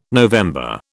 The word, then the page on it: November: British English